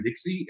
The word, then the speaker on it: Dixie: You can also plug in Kevin Dixie. My last name is spelled D as in dog, IXIE.